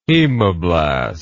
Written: hemoblast

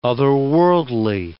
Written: otherworldly

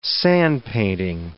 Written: sandpainting

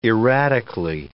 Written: erratically

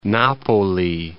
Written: Napoli

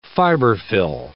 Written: fiberfill

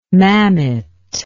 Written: mammet

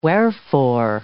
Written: wherefore

